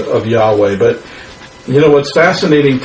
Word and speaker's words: Yahweh: Um, his most special name was Yahweh. You know, was that's how they pronounced it.